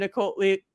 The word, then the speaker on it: Niccoli: Niccoli. It doesn't say like cannoli, but I know that's how it's pronounced.